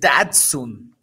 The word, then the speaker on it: Datsun: ¿Qué le puedo hacer un Daxun? Puedes pronunciar bien su nombre, Sergio. es Datsun, con de Tito.